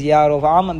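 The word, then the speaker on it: Yarovam (ירבעם): I don't know why they translated it with an E, but the correct pronunciation of his name is Yarovam ben Nevat.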